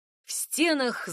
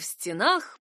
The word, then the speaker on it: стенах: Вспоминайте это двустишие всякий раз, когда сомневаетесь, какое ударение выбрать — в стенах или в стенах. И тот, и другой вариант имеет право на существование. Все зависит от того, о каких именно стенах идет речь.